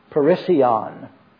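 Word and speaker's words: parisia (περισσεία): S-U-P-E-R-F-L-U-I-T-Y, superfluity. It's the Greek word parisia, P-E-R-I-S-S-I-A-H, is the way you pronounce it.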